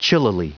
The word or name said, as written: chillily